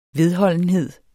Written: vedholdenhed

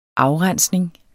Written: afrensning

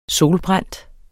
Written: solbrændt

